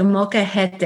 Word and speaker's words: molcajete: But even in your book, your story starts with the gifting of is it called a molcajete? – You're so close. It's a molcajete, and it's molcajete.